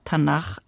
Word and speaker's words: Tanach: Tanach ausgesprochen okay.